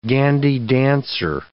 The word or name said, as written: gandy dancer